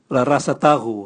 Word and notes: rarasatagu: Raga

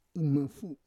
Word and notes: imafu: Roon

